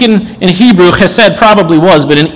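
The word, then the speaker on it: chesed (חֶסֶד): we would find the Hebrew word chesed. You can think of it as H-E-S-E-D to spell it in a way that would make sense to an English speaker. But I have trusted in your chesed.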